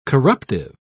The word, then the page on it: corruptive: British English